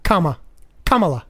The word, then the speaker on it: Kamala: Kamala. I said it right the first time. – No, you didn't. It's think of a comma. – Kamala.